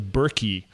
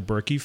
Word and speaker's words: Berkey: Berkey water filter. So it's spelled B-E-R-K-E-Y.